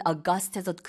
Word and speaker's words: august: 그래서 이 8월 August랑 스펠링이 같은데 발음은 August에서 그 뒤에 부분에 액센트가 들어가고요.